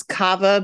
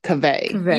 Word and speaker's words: Kaveh: I thought it was Kava, but I was doing the story today and he says Kaveh. – Yeah. I thought it was Kava too, but I think it is Kaveh.